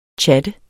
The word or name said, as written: tjat